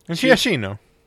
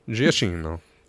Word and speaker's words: Giacchino: Giacchino. – Giacchino.